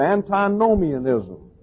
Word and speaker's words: antinomianism: Now let's take a look at antinomianism. I have it up on the wall here. It's spelled A-N-T-I-N-O-M-I-A-N-I-S-M. Antinomianism.